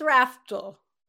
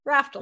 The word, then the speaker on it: Raftl: It's an L on the end. It confuses everybody. – It does. It's Raftl? – It's Raftl. Raftl.